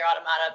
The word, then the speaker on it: Automata: The word is pronounced Automata, but in the game itself, they say Automata.